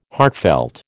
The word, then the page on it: heartfelt: American English